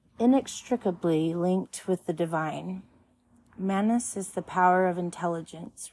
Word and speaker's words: Mannaz: The next one is Mannaz, Phonetic value is M. Pronunciation, man, ness.